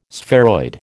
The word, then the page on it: spheroid: American English